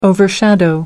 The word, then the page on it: overshadow: American English